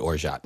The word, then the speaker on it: orgeat: I always said orgeat, but the T is silent? – The T is silent.